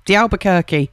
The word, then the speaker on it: D'Albuquerque: That was ELO and Showdown because it is Mike D'Albuquerque. That's how you pronounce his name, D'Albuquerque.